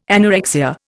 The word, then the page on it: anorexia: Indian